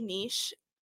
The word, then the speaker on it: Niche: So, well, I already have this app called Niche.